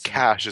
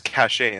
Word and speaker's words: cachet: Yeah, like cash is in C-A-C-A-H-E, you know, it's like... – Cachet. – It's cachet in French because it has the accent on the E, you know, but we call it cash, which, you know.